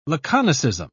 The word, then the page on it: laconicism: British English